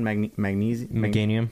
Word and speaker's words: Meganium: Meganium.